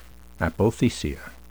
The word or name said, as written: apothecia